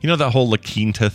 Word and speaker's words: La Quinta: La Quinta is Spanish for the Quinta, and it's pronounced La Quinta.